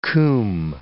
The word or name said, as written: combe